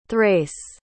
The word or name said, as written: thrace